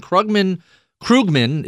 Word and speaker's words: Krugman: And when Krugman is the correct way to pronounce it,